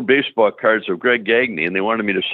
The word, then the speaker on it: Gagne: It's a French name. It's really Gagne is the way it's pronounced.